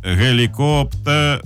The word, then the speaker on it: гелікоптер: От напишіть нам вайбер, як ви вважаєте, де наголос у слові гелікоптер... А? Де? А? А я знаю вже тепер. Гелікоптер. – Терне, так. – А? І це ваша заслуга. Ну, причому ви з двох боків мені одночасно вказали, прям так, ну, було, звісно, неприємно. Це чути, але це дійсно дієво, і тепер я знаю, що гелікоптер – це гелікоптер.